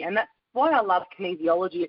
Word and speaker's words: kinesiology: So just for my own benefit, can you just explain what kinesiology is? And I hope I've got the pronunciation right. – Yeah, you have.